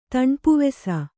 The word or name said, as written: ತಣ್ಪುವೆಸ